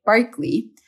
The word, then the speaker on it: Berkeley: A conversation that would make the great philosopher after which Berkeley is named, curiously actually pronounced George Berkeley, very proud.